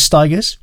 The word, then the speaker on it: Stigers: performed here by Curtis Stigers, rhymes with tigers, in brackets, what's so funny about, close brackets, peace, love and understanding.